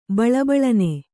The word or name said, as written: ಬಳಬಳನೆ